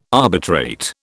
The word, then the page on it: arbitrate: British English